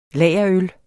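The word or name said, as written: lagerøl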